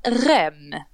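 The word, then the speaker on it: Reims: Eh, men han, han, eh, den unga killen från, eh, vad säger man, Rem. Mm. – Det är så man uttalar det va?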